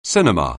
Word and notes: cinema: anglais

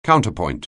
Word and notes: counterpoint: anglais

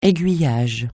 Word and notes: aiguillage: français

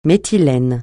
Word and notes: méthylène: francais

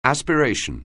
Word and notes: aspiration: anglais

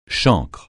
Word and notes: chancre: francais

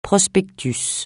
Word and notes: prospectus: francais

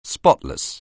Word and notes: spotless: anglais

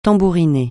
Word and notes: tambouriner: francais

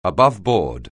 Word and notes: aboveboard: anglais

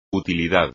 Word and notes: utilidad: espagnol

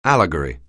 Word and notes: allegory: English

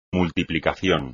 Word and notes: multiplicación: espagnol

